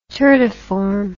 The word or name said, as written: turdiform